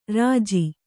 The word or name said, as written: ಋಜು